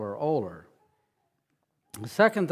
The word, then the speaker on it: Olar: And that town is spelled O-L-A-R, and we pronounce it Oler.